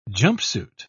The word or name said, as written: jumpsuit